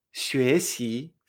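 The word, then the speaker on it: 学习: El verbo estudiar en chino se dice xue xi. Fíjate que me extraño dos veces.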